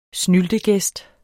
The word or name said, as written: snyltegæst